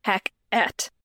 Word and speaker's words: Hecate: Now, for the purposes of this play, Hecate is pronounced with two syllables, Hec-et, based on how the line is written. This is different than the pronunciation of the Greek goddess Hecate, even though they appear likely to be the same.